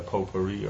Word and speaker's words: potpourri: And you say the potpourri or what? – Potpourri.